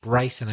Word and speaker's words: Bryson: He's pronounced Bryson. I think you said Bree.